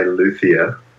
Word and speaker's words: luthier: We chatted about being a, how do you say it? Is it a luthier? Is it a luthier? Is it a luthier? – Most people say luthier.